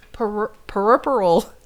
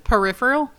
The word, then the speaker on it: puerperal: puerperal fever. – Puerperal? – Peripheral? – Yep. It's pronounced P-Y-O-O-P-E-R-A-L.